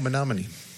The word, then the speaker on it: Menominee: Headlines, you almost said it right. Menominee Falls, Wisconsin. – Oh, Menominee. – Menominee. Is that where that song came from? Mm-hmm. So Menominee Falls, and someone from Wisconsin is going to call in and throw a beer at me straight through the emails and hit me because of I couldn't pronounce Menominee. – That's like 90% of our listeners. – Menominee. You're right.